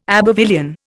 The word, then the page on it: Abbevillian: Indian